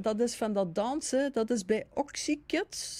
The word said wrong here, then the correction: Oxygen: Zeg ik dat goed? – Oxygen. – Oxygen. Oh, hij zegt Oxykids. Oxygen, dat is van...